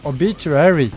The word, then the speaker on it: Obituary: Prononce-le bien. – Obituary. – Bravo, champion.